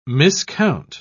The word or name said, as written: miscount